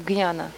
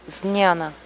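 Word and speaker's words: jñāna: В некоторых других языках произносится как гняна, а правильно Зняна, зняна.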